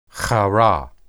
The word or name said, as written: harah